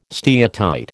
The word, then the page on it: steatite: American English